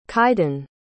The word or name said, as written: kyden